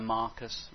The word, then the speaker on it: Marcus: I just wrote down here, these three, Aristarchus, John Mark, Mark, in the old version you get a bit of Marcus, rhymes with Aristarchus, like it.